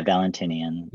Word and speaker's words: Valentinian: Yeah, Valentinian.